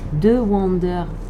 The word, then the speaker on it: Dewander: C'était Stellaroid de The Wonder. The Wonder. Vous pouvez, penser j'ai un accent anglais pourri. Et en fait, non, ça s'écrit D-E-W-A-N-D-E-R. Voilà, The Wonder.